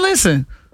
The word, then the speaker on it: listen: Listen is another one where the English words has a silent letter. – Silent and listen? – T. Listen. You ain't no T in listen.